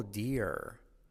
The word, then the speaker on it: dear: Oh dear. Oh dear. Eso es una I más R, ¿vale? Dear. Claramente una I y después R. Dear, como dos sílabas.